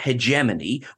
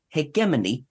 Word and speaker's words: hegemony: Okay, so I've been pronouncing it hegemony, which I have learned is the American pronunciation, but apparently hegemony is how it is pronounced in UK, Ireland.